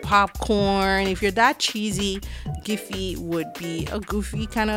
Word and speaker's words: Giphy: Giphy is spelled G I P H Y, like, you know, a GIF, um, but, you know, Giphy.